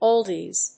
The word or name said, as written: oldies